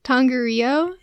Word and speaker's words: Tongariro: Yeah, I can't pronounce the national par- park, but it's Tongaria. – I know. – Tongariro National Park.